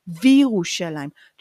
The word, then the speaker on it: וִירוּשָׁלַיִם: Sometimes ve becomes v if you have ye. In this case, Yerushalayim is starting with ye. So ve Yerushalayim, in order to ease pronunciation, becomes Virushalayim. Try to say ve Yerushalayim and Jerusalem, and then try to pronounce it Virushalayim and see that Virushalayim is easier, but basically it means and Jerusalem.